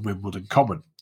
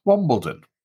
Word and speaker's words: Wimbledon: The story goes that Elizabeth used to take her children walking on Wimbledon Common and that her daughter mispronounced it Wombledon, which led her to make up stories for the children about Wombles.